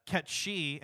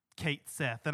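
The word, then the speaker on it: Cait Sith: So, and then Yuffie comes in, of course, and I believe Ketshi, and I'm going to pronounce it that way because I refuse to accept Kate Sith. And I know that that's like the canonical pronunciation now, and that pisses me off because it's a Gaelic name. It's supposed to be Ketshi. – Right.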